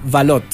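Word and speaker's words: Valotte: Mira, 1984, un disco que se llama Valot, se escribe Valote, escúchenlo, no tiene desperdicio ese álbum de Julian.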